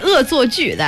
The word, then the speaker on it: 恶作剧: Well, so we say 恶作剧, that's how you say it in Chinese. – 恶 what? – 恶作剧. Yes, put them together, it's a set phrase, a set word.